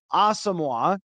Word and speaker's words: Asamoah: And then once they get a little bit more playing time, then they'll say, hey, actually, it's not how the way you've been pronouncing it. So Brian Asamoah is how it is pronounced, according to him.